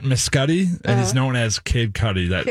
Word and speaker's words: Cudi: Kid Cudi. – Kid Cudi. Cudi, like Cudi Sark.